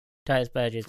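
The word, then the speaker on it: Tituss Burgess: Titus Burgess, is that how you pronounce his name? – Mm-hmm. – Titus Burgess does a version of it and it's incredible.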